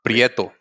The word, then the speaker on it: Prieto: So, in honor of smoking the, I can never say it right, Prieto. – Prieto. – Prieto. Wow. It sounds so much better when he says it.